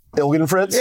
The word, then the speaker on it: Ilgenfritz: Did I pronounce it right? – Ilgenfritz? – Yeah. – You did. That's correct.